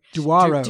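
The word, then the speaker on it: Douro: And just so you know, the competition is not about who can pronounce the name of the river Duro. – Duaro. – Duaro. – Duaro. That one. The one in Portugal.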